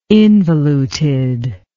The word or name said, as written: involuted